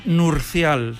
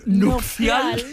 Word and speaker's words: nupcial: Sí, es que yo digo que hasta los 13 años pensé que era nurcial. – Nurcial. – ¿Ah, sí? – Y claro, me cuesta decir nupcial, que lo he dicho mal.